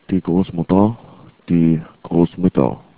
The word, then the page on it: grossmutter: german